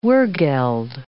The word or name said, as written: wergeld